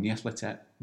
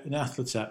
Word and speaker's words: Nyarlathotep: And Nyarlathotep, I think we actually diminish the status of the Dark Lord by speaking it in a Bolton accent.